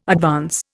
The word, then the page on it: advance: Indian